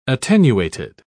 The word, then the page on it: attenuated: British English